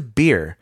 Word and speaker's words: Beer: And from there, they journeyed to Beer. – Love it. – That's correct. Yum. It's spelled B-E-E-R. – Loving it. – Just like Beer.